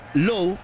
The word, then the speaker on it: Lowe: Sí, esa es una de las cosas más raras, porque tú dices Nathaniel Lowe, se escribe igual el apellido, L-O-W-E. Ahora, hay que preguntarle a ellos por qué uno se pronuncia Lowe y el otro Lowe, pero es así exactamente.